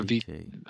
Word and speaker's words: Vitae: It's called Umbra Vitae or Umbra Vitae. – Vitae, I think it's actually pronounced. It's Latin for Shadow of Life, which is also the name of the record.